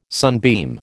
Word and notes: sunbeam: American English

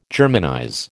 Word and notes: Germanize: American English

